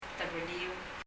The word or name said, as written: tebelir